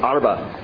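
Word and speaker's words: arba (ארבע): The word arba, or arba, I'm trying to say it like Cheryl's trying to teach me to say it, arba.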